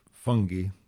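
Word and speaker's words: Fungie: named Fungi, as they pronounced him, like, you know, fungi.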